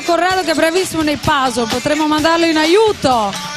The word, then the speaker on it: puzzle: Si scrive puzzle e si pronuncia puzzle.